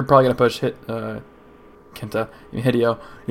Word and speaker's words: Hideo: Is it Hideo? – I was pronouncing it wrong. It's pronounced Hideo.